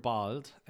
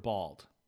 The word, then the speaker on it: bald: And it's pronounced very like essentially the exact same. Bald. – Mm-hmm. Yeah. Bald. And I guess I pronounce them a little differently. Bald would be German and then bald. – Yeah, that's a good point. – The A is a little different, but it's still really close.